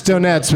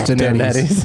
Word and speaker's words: Donettes: It's Donettes, but it's a running gag on the show that... – First timer. – Emma Arnold, on this very stage, admitted that her whole life she thought they were pronounced Donettis.